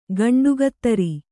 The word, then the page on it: ಗಂಡಗತ್ತರಿ: kannada